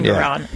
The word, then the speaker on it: Engoron: Is that how you pronounce his name? Engoron? – Engoron, yeah.